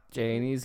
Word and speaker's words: Janie: They're really pronouncing the E on that. That's all. It's just Janie.